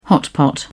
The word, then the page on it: hotpot: British English